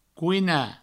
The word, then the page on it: coinar: couina